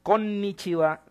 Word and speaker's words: Konnichiwa: El buenos días, pues eso lo conoce mucha gente, aunque poca gente lo pronuncia bien. Konnichiwa, la N es larga.